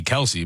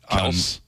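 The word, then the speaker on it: Kelce: I think, well, we say Kelsey, but I think Kels. On the podcast, he said their last name is actually Kels.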